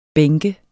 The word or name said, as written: bænk